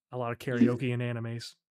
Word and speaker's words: karaoke: And then they go to karaoke, and I wrote down how they said karaoke. She said. You said karaoke. – Yeah. – Okay. Yeah. Well, that's because how you pronounce it in Japanese.